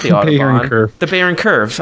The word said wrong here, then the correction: Byron Curve: Byron Curve. Apparently, I've been saying it wrong forever.